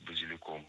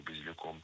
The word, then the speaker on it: базиликом: Постное тогда я бы взял, очень мне нравится в последнее время сочетание, это клубника с базиликом, свежим зеленым именно базиликом. – Так. – Базиликом, неверно, правильно, базиликом. В слове базилик ударение падает на третий слог, да там и остается, только базилик, базилика, базиликом.